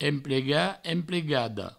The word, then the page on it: emplegat: emplegada; emplega; emplegado